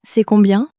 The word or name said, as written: c'est combien